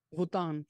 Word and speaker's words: Vuitton: How do you say his name? Louis? – What, Louis Vuitton? – Vuitton. – Vuitton. Louis Vuitton. Yes.